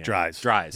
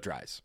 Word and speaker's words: Dries: Dries. – Like fries, but Dries.